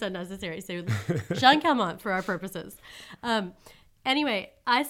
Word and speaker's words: Calment: We're going to say Calment because that's how it's spelled. In the South, there's kind of like a region. I think a lot of people where she's from say Calmaine.